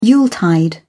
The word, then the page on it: yuletide: British English